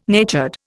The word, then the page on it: natured: Indian